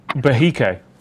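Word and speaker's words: Behike: Aaron says, Bahike is pronounced Bahike, which is obviously not what I said earlier on.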